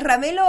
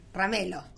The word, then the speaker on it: Ramelo: ¿Es Ramelo o Ramello? – Sí, sí. – Ramelo. – Ramelo. – Ah, Ramelo. – Bien. – Sí. – Anduve por ahí. – Tan notando. – Con O final. – Ramelo se pronunciaría.